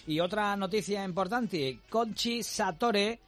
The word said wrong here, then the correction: Satorre: Satorre. – Satorre, lo digo bien.